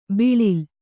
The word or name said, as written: ಬೀಳಿಲು